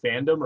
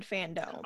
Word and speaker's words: FanDome: Also, side note, are you guys pronouncing it fandom or fandome? – I think I accidentally said fandom when I should have said fandome.